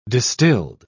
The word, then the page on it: distilled: British English